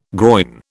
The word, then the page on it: groyne: British English